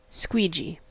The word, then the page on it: squeegee: American English